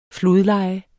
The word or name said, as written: flodleje